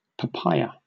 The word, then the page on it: papaya: British English